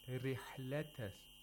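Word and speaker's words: رحلة: Now we have the opposite of tafkhim in the word rihlata. Rihlata shita. The ra in rihla has a kasra. – So it should be pronounced. – It should be pronounced with thinness, with tawqiq, with an empty mouth, like you said.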